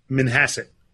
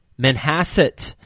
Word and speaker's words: Manhasset: Manhasset. – Manhasset.